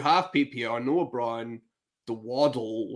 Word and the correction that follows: Dowdle: or however you pronounce it. – Dowdle.